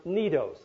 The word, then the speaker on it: Cnidos: Now, in the scripture, it's like Cnidus or something, but that really is a silent sea, and it should be pronounced Cnidos.